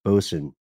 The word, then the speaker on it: boatswain: And boatswain is pronounced bosun.